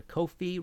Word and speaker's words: Ko-fi: If you like the show and want to support future episodes, please go to Ko-fi. Rhymes with no fee.